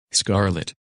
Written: Scarlett

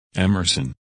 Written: Emerson

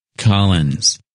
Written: Collins

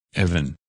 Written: Evan

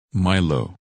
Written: Milo

